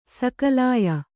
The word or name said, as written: ಸಾಕಲ್ಯ